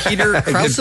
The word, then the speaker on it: Krause: Peter Krause, is that how you say his name? – Yes.